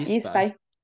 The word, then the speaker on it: Gissberg: Evelina Gissberg, eller hur uttalar vi efternamnet? Jag har inte tänkt på det förrän nu. Ja, ja Gissberg. Det är rätt.